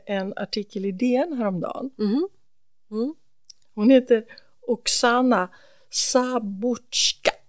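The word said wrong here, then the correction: Zabuzhko: Nej, Sabotsko, om jag uttalar det rätt.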